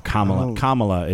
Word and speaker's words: Kamala: Or Kamala is how it's pronounced.